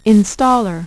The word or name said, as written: Installer